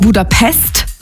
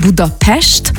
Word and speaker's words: Budapest: Also erstens appreciaten wir das schon mal, wenn man nicht Budapest sagt, sondern Budapest, weil das S immer ausgesprochen